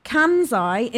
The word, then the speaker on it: Kansai: I'm going to think, I might pronounce the next one wrong. Kansai International Airport in Japan. It's spelled K-A-N-S-A-I. – Yeah, sounds about right.